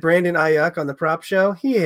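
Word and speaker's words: Aiyuk: Yeah, no, I think a guy who kind of like broke on the map a little bit, Brandon Ayuk. Did I say it right, buddy? – You got it. You nailed it. I'm so proud of you. – I wrote down A-I-Y-O-O-O-O-O-O-O-O-O-O-O-O-O-O-O-O-O-O-O-O-O-O-O-K.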